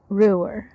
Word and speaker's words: Ruwer: Ruwer. – Ruwer. It's pronounced Ruwer. – With a W. – Yeah. – Okay. But it's R-U-W-E-R.